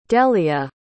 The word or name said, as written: dehlia